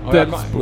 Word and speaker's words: Delsbo: Ja, fast grundordet är Dellen. – Ja. – Ja, så då måste man uttala det Delsbo.